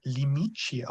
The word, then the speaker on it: Limite: Und der heißt Limité, auf Deutsch Grenze, von Mario Perretto. Sie können mich gerne korrigieren bei der Aussprache nochmal, von 1933. – Tatsächlich spricht man das Limite aus.